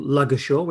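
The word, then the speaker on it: Ludgershall: We've got a team coming along called Luggershaw. We have to pronounce it that way.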